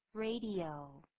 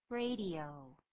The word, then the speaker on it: radio: 这个 radio 不要念成 radio, 不是这样念 radio, 应该怎么念呢？ – 三个音节 ,radio, radio.